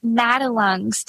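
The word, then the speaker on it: Madelung's: So when were you diagnosed, and I'm not sure that I am saying this right, but Madelungs, how do you say that? – Yeah, it's very rare, so I don't blame you for being unsure about the pronunciation. It's pronounced Madelungs deformity, and I can set the scene for you a bit.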